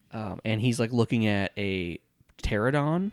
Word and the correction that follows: Pteranodon: I think that's how you say it. – Yep. – So bad at pronouncing dinosaur names. – Yeah, it's a pteranodon.